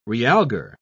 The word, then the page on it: realgar: British English